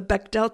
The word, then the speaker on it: Bechdel: I can never pronounce it correctly. The Bechdel test? – Oh, the Bechdel test.